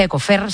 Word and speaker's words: Ecofire: Vamos a hablar con Ecofire, se pronuncia Ecofer, se dice Ecofer, un agente extintor orgánico capaz de frenar el fuego actuando como barrera térmica.